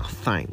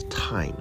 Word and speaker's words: thyme: 很多人会讲 thyme，但应该是 time 才对，像是时间的这个 time。